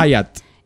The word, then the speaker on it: Hyatt: En una suite del hotel Hyatt, o no sé cómo se dice. – Hyatt.